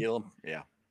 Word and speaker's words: Elam: There's like the Kyer Elam guy everybody loves, or Elam, however you say his name. – Elam, yeah.